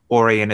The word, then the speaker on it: Orion: So one thing, just in case you didn't already know, Orion is pronounced Orion rather than Orion, which is a mistake that other people have made.